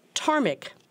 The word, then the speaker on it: ptarmic: And the adjective ptarmic, the first letter of which is a silent P, describes anything that makes you sneeze.